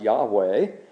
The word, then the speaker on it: Yahweh: Our God, whose name is pronounced Yahweh, our God is not going to leave things the way they are.